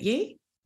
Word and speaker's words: Ye: Is it Ye? – Ye, I think you pronounce it. As in Kanye.